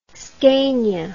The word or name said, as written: Scania